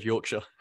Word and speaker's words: Yorkshire: I was smiling at the way that how you said Yorkshire. – Did I say it wrong? – I am from Yorkshire, so I can, yep. You're okay.